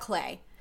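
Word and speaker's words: Clé: Oh, I love Clay. I love Clay. It's just for the people listening. It's spelled C-L-E.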